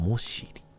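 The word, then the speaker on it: 母子里: えー、これが、えー、もしり。母の子の里と書いて、もしり。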